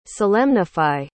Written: solemnify

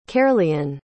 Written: caerleon

